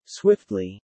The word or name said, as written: swiftly